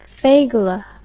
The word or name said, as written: fegelah